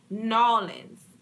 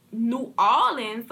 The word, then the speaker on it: New Orleans: We do not say Nawlins. I don't know why people think we say Nawlins. Now, we say New Orleans, like we say, we will say New Orleans, but we at least pronounce the new.